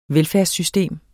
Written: velfærdssystem

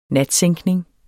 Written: natsænkning

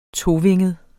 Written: tovinget

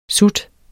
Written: SUTS

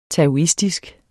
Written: taoistisk

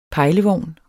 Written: pejlevogn

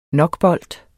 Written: nokbolt

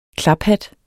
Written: KLAPHATTE

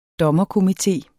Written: DOMMERKOMITE

